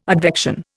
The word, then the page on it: advection: Indian